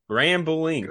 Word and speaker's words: Grambling: Grambling, right? Grambling. That's how it's pronounced.